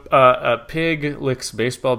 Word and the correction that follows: Puig: It's pronounced Puig.